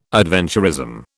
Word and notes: adventurism: British English

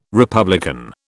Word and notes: republican: British English

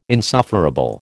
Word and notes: insufferable: American English

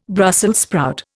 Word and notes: Brussels sprout: Indian

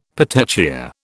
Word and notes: petechia: British English

